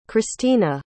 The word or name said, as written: cristina